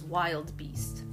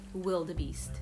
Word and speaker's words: wildebeest: I thought it was wild beast, but it is... No? Okay, wildebeest. It's the wildebeest.